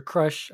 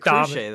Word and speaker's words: Krusha: I I really like this part and I really like uh Krusha's character, Krusha Crush. I kind of think Crush because she's they they pronounce it Cruche though.